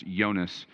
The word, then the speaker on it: Jonas: Latin, sometimes you'll see his name as Jonas, or you'd pronounce it in Latin as Jonas.